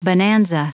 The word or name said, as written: bonanza